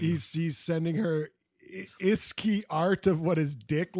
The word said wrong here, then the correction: ASCII: Yeah, am I saying it wrong? I don't even know what this is. – ASCII.